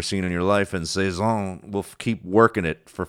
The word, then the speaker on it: Cezanne: And he said there's a lot of artists that are like, and he compared Cezanne. Is that how you say it? – Yes, Cezanne. Of course, Cezanne.